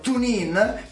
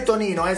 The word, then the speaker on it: TuneIn: deve farlo tramite o l'app TuneIn, che non è Tonino, si scrive TuneIn, oppure dal sito mradio.it.